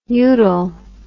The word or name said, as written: udal